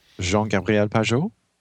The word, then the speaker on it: Jean-Gabriel Pageau: Jean-Gabriel Pageau? – Yes, thanks.